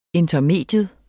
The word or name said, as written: intermedium